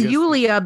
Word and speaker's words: Julie: Again, I think that the purpose of that scene and Julia's reaction to it, and her name is spelled Julie, but everyone in the movie says Julia. – It's Yulia because the E is a.